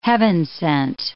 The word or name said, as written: heaven-sent